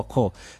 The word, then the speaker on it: corps: Sasa jina ambalo likaja kuzaa Kariakoo, kwani kwa Kiingereza neno carrier ama utamkwa kama carrier na corps utamkwa co.